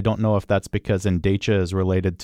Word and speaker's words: Endecha: It's spelled E-N-D-E-C-H-A. I think they said it was Endecha. Is that what it was? – Yes.